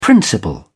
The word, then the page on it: principal: British English